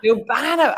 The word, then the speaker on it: Urbano: Urbano.